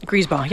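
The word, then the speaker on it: Griesbach: Is that how you say it? – Griespaw, yeah.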